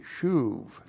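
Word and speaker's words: shuv (שוב): That's S-H long U-B, shuv.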